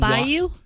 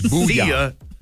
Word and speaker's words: Booyah: Booyah, not Bayou? – No, not Bayou. B-O-O-Y-A-H. Booyah.